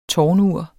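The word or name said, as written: TÅRNUR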